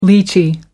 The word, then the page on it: lychee: American English